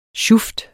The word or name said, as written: sjuft